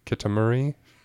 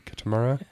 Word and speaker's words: Kitamura: Rye Kitamuri. Kitamura.